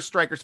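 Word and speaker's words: Stryker: Follow your host, Steeler Nation Striker, on Twitter and Instagram at Steeler Nation at SN Striker, Striker spelled with a Y.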